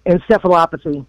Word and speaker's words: encephalopathy: So they call, at the CDC, they call prion diseases or transmissible spongiform, I'm guessing that's how you pronounce that, and encephalopathies. Is that right? – Encephalopathy.